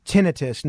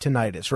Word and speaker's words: tinnitus: The humidity that we've had the last week or so, It's all summer long in Florida, and it is pronounced tinnitus, not tinnitus, right?